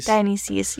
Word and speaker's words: Dionysus: And all the gods have these businesses where, like, I don't know how to say his name, but Dionysus, I think is how you say that. – Dionysus, yes.